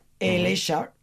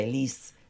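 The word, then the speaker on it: elexa: Elesha, aquí no se dice elis, se dice Elesha.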